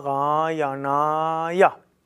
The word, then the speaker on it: Narayanaya: Om. Namo. Das ist das O. Na. Langes A. Ra. Ja. Kurzes A. Na. Das ist das zerebralisierte Na. Na, Narayanaya. Om Namo Bhagavate Va, langes A, Su Da De Va, langes A, Ja.